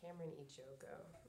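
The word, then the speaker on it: Carmen Ejogo: So I think it's Cameron Ejogo, for how to pronounce her name. – Yeah, something like that.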